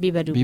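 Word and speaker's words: Beabadoobee: Also eine ähm, japanischstämmige äh, Britin ist das ähm, Biba Dubi, wobei ich auch nicht weiß, ob man sie Bea Badubi ausspricht. Ja, ich glaube Biba Dubi. Biba Dubi ist richtig.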